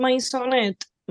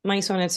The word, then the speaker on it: Maisonet: Si escriben mi nombre, Janice Maisonet, Maisonet se escribe con ida.s, así como se escucha, eh, me van a encontrar, pero el handle de Instagram es Nanny Sachs, N-A-N-Y Sax.